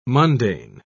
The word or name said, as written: mundane